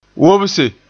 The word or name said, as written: wobse